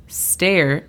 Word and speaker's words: stair: D-I-S-H. – Dish. – Muy bien. Después tenemos stair. Stair significa escalera. La pronunciación fonética es S-T-E. Imagínate que hay un guión separando S-T-E de la R.